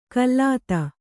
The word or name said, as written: ಕಲೆತ